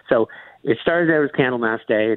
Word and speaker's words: Candlemas: is it pronounced Candlemas Day? – That's right. Yeah.